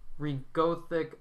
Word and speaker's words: regolith: Lunar dust, regolithic, okay, so it's a reflective regolith, material. Regolith. – That's how you say it. It's confirmed.